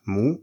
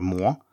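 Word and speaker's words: mu (模): You will then find that mu is pronounced mu or mo and means pattern, to copy, and not to stroke, to touch, as you thought.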